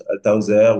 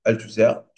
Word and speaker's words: Althusser: Althauser ou Althusser, je crois que ça se dit en français.